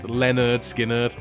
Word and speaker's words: Lynyrd Skynyrd: Well, on the album was actually called, pronounced Lynyrd Skynyrd.